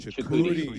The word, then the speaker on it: Chukwudi Iwuji: Did I pronounce it cor- correctly? Chukwudi. Chukwudi Iwuji. Yeah.